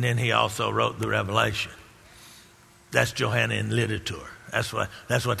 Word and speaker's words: Revelation: It's not Revelations, it's Revelation. Many people mispronounce that. They call it Revelation. No, it's Revelation.